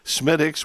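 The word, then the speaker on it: Smithwick's: But so just in case you haven't experienced Smittix, which is spelled Smithwix, go get some, and you might like it a whole bunch.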